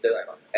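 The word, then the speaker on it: Thelema: And so the pronunciation is Telema, and it's a Greek word that means will, like the will to do things, the will to establish things, to manifest things.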